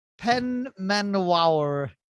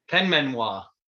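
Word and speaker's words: Penmaenmawr: It is instead, I think he's done this on purpose, Penmenwawr in Wales. That's obviously not how you pronounce that. – Penmenwawr. – That's about that, yeah. – Penmenwawr.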